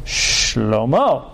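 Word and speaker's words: shlomo (שלמה): And the word for Solomon, as you would pronounce it in Hebrew, is shlomo, which means peace.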